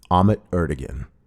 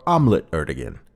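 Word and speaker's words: Ahmet Ertegun: Finally, here's a tape Jerry made for Atlantic Records founder Ahmet Erdogan, or Omelet Erdogan, as the doo-wop groups mispronounced it, for Ahmet's 80th birthday.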